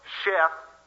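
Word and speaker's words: Sheth (שֵׁת): Seth, or Sheth, as it is pronounced in Hebrew, means set or appointing.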